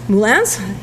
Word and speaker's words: moulins: Moulins? I'm not a glaciologist. I'm going to use a French pronunciation, pseudo-French.